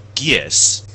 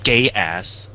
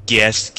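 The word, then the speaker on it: Geass: Best actor in a leading role is Jun Fukuyama as in Lelouch, called It's Gies, not Gay Ass. – Is that the official pronunciation? – Gies.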